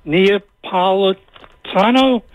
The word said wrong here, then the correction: Napolitano: I think that's the way he pronounced it, from Fox News. – Napolitano, Judge Napolitano.